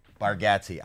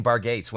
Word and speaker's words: Bargatze: Yeah, that's how we say it. Bargatze. I was saying Bargates when I was uh, loading. Do people say it wrong all the time? – Oh, they say yeah. I honestly think our family says it wrong.